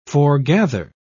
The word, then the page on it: foregather: British English